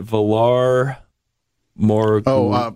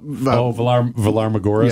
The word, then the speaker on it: Valar Morghulis: And I'm going to butcher this like I butcher all things, but Valar Morghulis. – Valar Morghulis. – Yeah. I think it's Valar Morghulis.